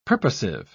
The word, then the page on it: purposive: British English